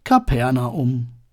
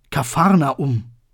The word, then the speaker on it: Kapernaum: Nein, Kapernaum. Jesus zieht nach Kapernaum. Anders ausgesprochen, Kapharnaum. Es heißt übersetzt das Dorf von Nahum, Kapharnahum im Original.